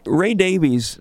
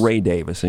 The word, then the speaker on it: Davies: By the way, Ray Davies, that's the way most people pronounce it. We had Ray himself on the show. – Yes. – Ray Davis, in case anybody's going to complain.